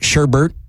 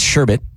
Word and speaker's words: sherbet: I hate people that pronounce it sherbert. It's sherbet.